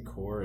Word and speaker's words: Corps: Is it because the S in Corps is silent?